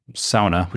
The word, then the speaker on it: sauna: If you've grown up overseas and you've experienced a real sauna, which is how we say it properly,